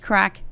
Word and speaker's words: craic: But C-R-A-I-C, spelt but pronounced crack, means fun in Ireland.